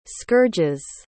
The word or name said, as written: scourges